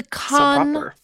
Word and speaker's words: Cannes: Our final story is about the Cannes Film Festival, which that's how I'm going to pronounce it, okay? It's the Cannes Film Festival.